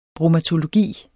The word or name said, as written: bromatologi